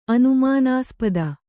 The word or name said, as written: ಅನುಮಾನಾಸ್ಪದ